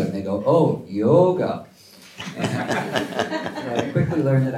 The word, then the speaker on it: yoga: There's long vowels and short vowels in India, so yoga is a short vowel, and yoga, how Tom pronounced it, is actually the correct way of pronouncing it.